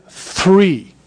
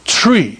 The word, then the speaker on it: three: So if you say, okay, I want you to pronounce this word, and it's the word three. Tree. No. Three. Tree. Because they don't have that combination.